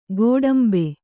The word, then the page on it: ಗೋಡುಂಬ: kannada